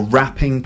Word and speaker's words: wrapping: W would be the wise men, or wrapping paper, that's WRAPPING, to wrap. A silent W, isn't it?